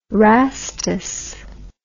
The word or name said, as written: Rastus